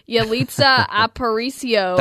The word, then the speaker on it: Yalitza Aparicio: Yalitza Aparicio.